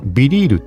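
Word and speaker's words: BeReal: そこにこのアプリはビリールって読みますって書かれてんの。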